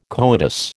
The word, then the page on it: coitus: American English